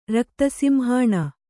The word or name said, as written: ರಕ್ತಸಿಂಹಾಣ